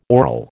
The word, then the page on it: oral: American English